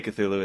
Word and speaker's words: Cthulhu: The reason they call it that is because Cthulhu, which we say Cthulhu, it's supposed to be an unpronounceable kind of name.